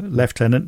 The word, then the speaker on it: lieutenant: British pronunciation? – Yes, sir. – Lieutenant, not lieutenant. – No, even though it's still spelt lieutenant. – Well, it's a French spelling, isn't it? – Yeah, well, yeah.